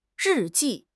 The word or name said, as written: ri4 ji4